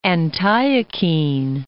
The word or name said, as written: Antiochene